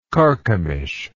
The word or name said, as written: charchemish